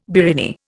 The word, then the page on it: biriani: Indian